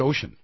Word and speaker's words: Ossian: Now, Kevin Boyle, your book, Arc of Justice, which traces the Ossian, I've been mispronouncing his name all the time. It's Ocean.